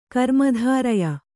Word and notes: ಕರ್ಮಧಾರಯ: karmadhāraya

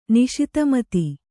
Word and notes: ನಿಶಿತಮತಿ: kannada

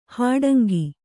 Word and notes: ಹಾಡಂಗ: Kannada

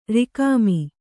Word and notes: ರುಕ್ಮ: Kannada

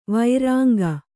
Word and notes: ವರಾಂಗಿ: varāngi

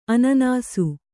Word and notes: ಅನಾನಸ: Kannada

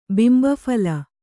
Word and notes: ಬಿಂಬಫಲ: bimba phala

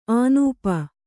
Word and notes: ಆನೂಪ: ānūpa

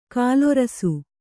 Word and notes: ಗೋಲರಸ: gōla rasa

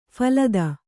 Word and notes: ಫಲಿತ: phalita

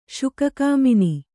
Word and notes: ಶುಕಕಾಮಿನಿ: Kannada

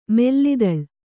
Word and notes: ಮಲ್ಲದಾಳ: malla dāḷa; Kannada

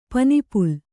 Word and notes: ಪನಿಪುಲ್: Kannada

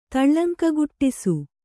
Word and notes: ತಳ್ಳಂಕಗುಟ್ಟಿಸು: kannada

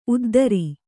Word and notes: ಉದ್ದರಿ: uddari

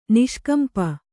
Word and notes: ನಿಷ್ಕಂಪ: Kannada

